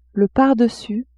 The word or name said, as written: pardessus